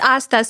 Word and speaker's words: Astha: Yes, Asta. Better than most people really? – Here. – Asta. – Yeah. It's Asta. So I tell people it's like pasta, but just remove the P.